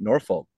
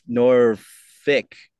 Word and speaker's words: Norfolk: They don't say it Norfolk. It's Norfolk. – Norfolk. – Even though... Yeah, even though it's spelled Nor, F-O-L-K, but hey, it's a local thing, so.